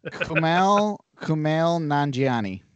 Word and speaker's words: Kumail Nanjiani: Kumail Nanjiani. I know him, but I don't know how to pronounce his name. – Kumail Nanjiani.